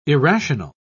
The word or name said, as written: irrational